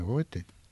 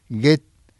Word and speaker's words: Goethe: Sí, se pronuncia Goethe. ¿Cómo es? – Goethe.